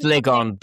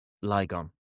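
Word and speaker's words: Lygon: It's pronounced Ligon, but if you want to find it, Ligon. – Ligon, yeah. You find it more easily.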